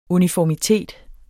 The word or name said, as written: UNIFORMITET